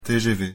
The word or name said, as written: TGV